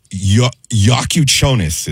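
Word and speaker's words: Jakučionis: And then the guard, Yakuchonis is how you say his last name.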